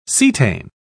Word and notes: cetane: British English

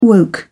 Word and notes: woke: British English

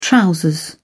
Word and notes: trousers: British English

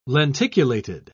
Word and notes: lenticulated: British English

